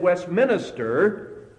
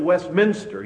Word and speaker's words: Westminster: He had heard it wrong, and he always said Westminster instead of Westminster. He made a four-syllable word out of a three-syllable word.